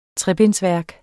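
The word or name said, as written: trebindsværk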